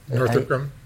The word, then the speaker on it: Northrop Grumman: Northrop Grumman.